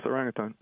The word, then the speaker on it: orangutan: the Sumatran orangutan. Have I pronounced that correctly? – Mm-hmm. Yes, orangutan. Mm-hmm.